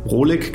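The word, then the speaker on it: Rohlik: Äh, wie werden sie ausgesprochen? Rolik, genau, und Flink, also quasi was passiert im LEH in Deutschland.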